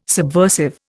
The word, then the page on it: subversive: Indian